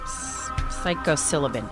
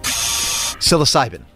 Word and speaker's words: Psilocybin: I said this wrong every time. Psilocybin. – Psilocybin.